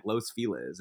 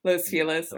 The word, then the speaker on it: Los Feliz: It's like Los Feliz. It's like that's not the proper way to pronounce it, and it just became the proper way to pronounce the word.